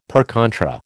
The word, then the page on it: per contra: American English